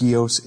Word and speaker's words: scio: I think it's pronounced scio, S-C-I-O, which means to know.